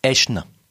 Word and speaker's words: Aeschne: Qui s'écrit A-E-S-C-H-N-E. Là, je vais dire au Scrabble, tu mets du point, et qui se prononce Eschne.